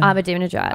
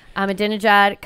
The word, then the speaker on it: Ahmadinejad: Ahmadinejad. Abdum, uh, see, I used to know how to say it. – Ahmadinejad.